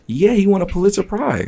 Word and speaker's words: Pulitzer Prize: Didn't Kendrick Lamar win the, I want to, it's pronounced the Pulitzer Prize.